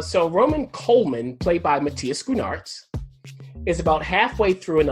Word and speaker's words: Schoenaerts: Matthias Schoonartz.